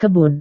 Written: kebun

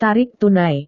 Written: tarik tunai

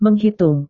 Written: menghitung

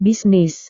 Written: bisnis

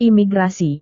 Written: imigrasi

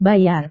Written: bayar

